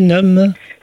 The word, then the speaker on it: NUM: Alors bien évidemment, quand on parle de sport NUM, ou c'est bien prononcé NUM Sport NUM, ah oui, comme numérique.